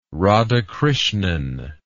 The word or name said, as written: radhakrishnan